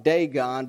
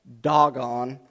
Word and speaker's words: Dagon: It looks like Dagon, but it's actually pronounced Dagon.